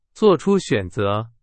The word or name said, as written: 选择